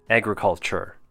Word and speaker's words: agriculture: When pronounced, it's A. Gr, short U sound. Cull, think of the word cull, C-U-L-L. And chur, C-H sound with an E-R ending. Agriculture. And our last one for pronunciation would be three linking words.